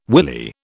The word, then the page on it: willy: British English